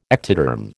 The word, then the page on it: ectoderm: American English